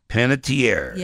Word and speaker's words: Panettiere: I could never remember how to pronounce her last name again. – Panettiere. – Yeah.